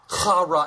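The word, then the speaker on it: Chara (χαρά): Chara. Everybody say that with me. Chara. Right? You got to say it like you got some phlegm in your throat.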